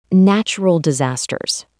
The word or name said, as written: natural disasters